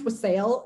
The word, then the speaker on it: wassail: that is spelled W A S S a I L, yeah. which to me should be pronounced wassail and it like annoys me that it's not, but I know it's wassail because there's that song, Here We Come a Wassailing.